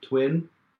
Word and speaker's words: TVVIN: So even though it's two Bs, pronounced TWiN, the idea actually came to Lisa in a dream, if the truth be told.